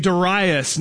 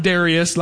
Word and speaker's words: Darius: 522 BC, a king named Darius, not Darius like the country music singer, Darius, spelled that way but not pronounced that way.